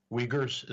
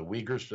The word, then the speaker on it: Uyghurs: Now, the Uyghurs, is it the Uyghurs? Did I pronounce it right? – I think that's right.